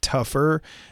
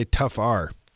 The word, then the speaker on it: Tougher: Uh, is that how you say it? Do you say tougher or do you say tough R? – I say tougher. Um, the EPA might not like it, but it's tougher. Um, but it's spelled tough R.